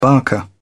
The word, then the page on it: barker: British English